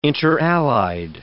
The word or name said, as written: interallied